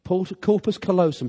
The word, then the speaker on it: corpus callosum: That there are approximately 300 million nerve fibres called, I'm looking at Liz, corpus callosum. Have I pronounced it right? Corpus callosum. Thank you for correcting the vicar.